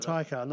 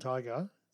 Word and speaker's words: Taika: So basically, Jack Black, he contacted Taika Waititi and said that he put a video together. – Taika, not Tiger.